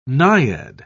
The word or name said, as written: naiad